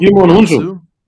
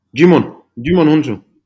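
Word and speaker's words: Djimon Hounsou: Jimon Hounsou. – Yeah. So how do you pronounce that again? – Jimon Hounsou.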